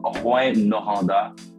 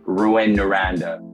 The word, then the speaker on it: Rouyn-Noranda: So it's called Rouyn-Noranda. And if you would want to say it, I guess, with the English pronunciation, it would be like Rouyn-Noranda or something like that.